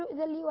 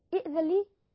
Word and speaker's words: ائذن: كلمة ائذن لي في حالة الوصل نقول ومنهم من يقول ائذن لي ويتفتني، يعني ننطق بالهمزة، اجتمع نعم سبب المد لكن لم يأتي حرف المد، لكن في حالة البدء، هذا في حالة الوسط، في حالة البدء بإئذن لي نقول ماذا؟ إئذن لي.